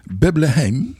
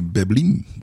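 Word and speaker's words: Beblenheim: Uh, Bebleheim ja. is een Frans plaatsje, dus je moet het op zijn Frans uitspreken. Waarschijnlijk eh, Beblin of zoiets. Bebelin.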